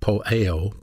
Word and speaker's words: poieo: The word do, D-O, is a little small word pronounced poeo, poio, P-O-Y-E-H-O, poeo, we pronounce it that way. It's spelled P-O-I-E-O, poeo. It's a verb.